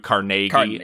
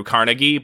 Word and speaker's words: Carnegie: Technically, it's Andrew Carnegie and not Andrew Carnegie, but I will say Carnegie.